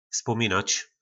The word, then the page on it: Vzpominac: Po našimu